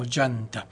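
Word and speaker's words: Déanta: Se escribe de Anta, pero se pronuncia algo así como Yanta.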